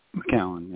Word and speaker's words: McCown: McCown, yeah.